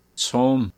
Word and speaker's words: tsum: Tsum. – Oh, okay. Well, it's pronounced tsum. I'm probably sure you could probably say tsum. But tsum is the way it's pronounced in this one. T-S-U-M.